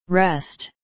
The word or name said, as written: rest